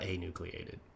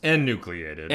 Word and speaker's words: enucleated: So we put in this toxin into this nucleus and anucleated cell. – Right, into the same little area, right? – Did I say that right? It's not anucleated. – It's enucleated.